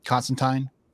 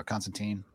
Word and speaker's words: Constantine: Yeah, man, I love the way they show the House of Mystery with Constantine or Constantine. – Whatever. No, you said it right the first time.